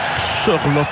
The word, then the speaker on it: Sørloth: Et normalement, on doit dire Sørloth d'ailleurs dans la prononciation norvégienne.